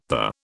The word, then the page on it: the: British English